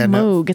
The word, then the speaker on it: Moog: He wrote, I finally accepted that Moog rhymes with Brogue.